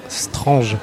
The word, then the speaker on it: Strange: Et on reconnaît les vrais fans, c'est qu'ils ne disent pas Strange, ils disent bien Strange. Ah oui, voilà, Strange. Cette prononciation très française qui est notre manière à nous, un peu comme le gendarme à New York, de percevoir l'Amérique.